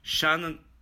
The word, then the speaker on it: Shantonio: Let me spell it for you. S-H-A-N-T-O-N-I-O. Like Shan Antonio. Shantonio.